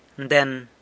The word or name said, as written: nden